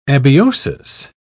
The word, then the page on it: abiosis: British English